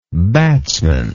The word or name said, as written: batsmanship